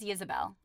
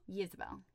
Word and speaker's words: Ysabel: I think it's Ysabel. It's spelled with a Y. – With a Y. – Yeah. – Ysabel? – Ysabel.